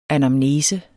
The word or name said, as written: ANAMNESE